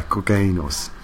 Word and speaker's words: cocaínos: I think that's where Pharaoh was supposed to go after he died with uh, burritos cocaínos. cocaineos. Oh, oh yeah, cocaínos. Sorry, bad pronunciation.